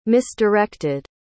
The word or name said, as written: misdirected